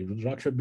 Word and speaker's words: Rudraksha: And you also talk about Rudraksha beads. Did I say that right? – Absolutely. Those are my favorite, actually. Rudraksha beads.